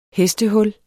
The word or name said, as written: hestehul